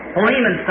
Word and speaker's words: Philemon: So, Philemon, someone said back there, which I think is really the correct pronunciation of it.